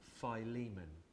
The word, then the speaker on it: Philemon: Now I looked up how to pronounce it, and I read one of those little sound bites that you can click on the internet, and it's Philemon.